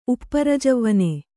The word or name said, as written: ಉಪ್ಪರಜವ್ವನೆ